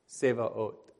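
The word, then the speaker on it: Sevaot (צבאות): So, for example, the very end here, so, Sevaot, Sevaot, that's how that's pronounced.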